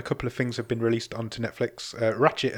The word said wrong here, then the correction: Ratched: has come out, or Ratched. How do you say that? Ratched? – Ratched. – Ratched. – I've been saying Ratchet my entire life until that program was released. – I think Ratchet is the Transformer.